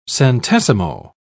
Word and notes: centesimo: British English

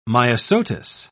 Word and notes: myosotis: British English

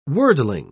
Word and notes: worldling: British English

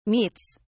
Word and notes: meats: British English

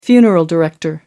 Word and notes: funeral director: American English

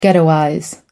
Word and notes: ghettoize: American English

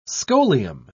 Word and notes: scholium: British English

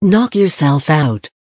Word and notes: knock yourself out: American English

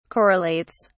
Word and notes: correlates: British English

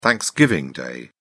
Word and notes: Thanksgiving Day: British English

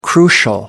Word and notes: crucial: American English

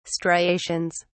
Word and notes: striations: British English

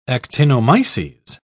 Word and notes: actinomyces: British English

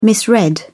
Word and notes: misread: British English

